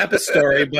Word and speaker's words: Epistory: I, I, I know it's epistory, but whatever.